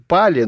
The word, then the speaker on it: пхали: Ну, я, кстати, неправильно произношу, он уже не совсем пхали, он как бы пали, но П такое, оно приглушенное, по-моему. По крайней мере, нам его так произносили, по крайней мере.